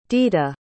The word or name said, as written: dita